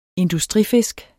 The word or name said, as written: industrifisk